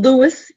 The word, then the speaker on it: Louis: National Louis University, not the French pronunciation.